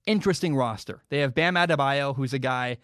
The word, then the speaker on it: Adebayo: Bam Adebayo, thank goodness he spells his name like you would. If you phonetically spell Bam Adebayo's name, you'd go, okay, that makes sense. That's what I would expect.